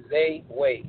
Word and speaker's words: Xayway: X-A-Y-W-A-Y, Zay Way. I had to make it different because it's my own way, so I had to put the X at the front, you know what I'm saying?